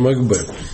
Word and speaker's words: Макбет: Макбет, ударение на Макбет. На Мак не ставится ударение.